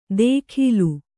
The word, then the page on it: ದೇಖೀಲು: Kannada